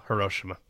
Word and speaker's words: Hiroshima: Hiroshima.